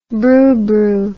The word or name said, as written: brubru